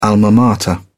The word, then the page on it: alma mater: British English